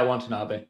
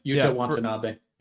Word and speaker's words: Watanabe: And good on Watanabe, is that how it's pronounced? – Yeah, Watanabe. Watanabe.